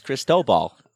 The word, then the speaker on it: Cristobal: Depends on where you emphasize the syllable. – It's Cristobal.